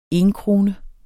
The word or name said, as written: enkrone